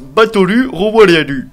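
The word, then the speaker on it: Batolu Royalu (バトル・ロワイアル): Et donc du coup, petite anecdote aussi en japonais, Battle Royale, ça se prononce Batolu Royalu.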